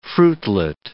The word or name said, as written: fruitlet